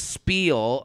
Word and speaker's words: spiel: I love Jared's spiel about spiel, right? That's how you say that. – Spiel, spiel. I think, yeah, is the Yiddish, is the Yiddish pronunciation.